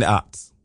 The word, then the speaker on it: aughts: Yeah, the aughts. Yeah. – Aughts. – Sorry. – How you spell that? – A-U-G-H-T-S.